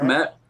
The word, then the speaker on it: Kmet: Cole Kmet. Matt.